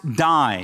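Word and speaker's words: die (δεῖ): In the Greek, the word is pronounced die. It has nothing to do with death or passing away. It's spelled not D-I-E, but D-E-I.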